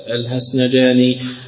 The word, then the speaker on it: الهسنجاني: وقوله لقد كفر الذين قالوا إن الله ثالث ثلاثة قال ابن أبي حاتم حدثنا علي بن حسين الهسنجاني حدثنا سعيد بن الحكم بن أبي مريم الهسنجاني في أصله حسن جاني لكن العجم ما يستطيعوا النطق الحاء فيقولون حسن، مثل محمد محمد.